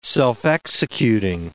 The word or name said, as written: self-executing